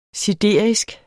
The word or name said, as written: siderisk